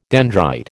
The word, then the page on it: dendrite: American English